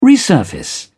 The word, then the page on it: resurface: British English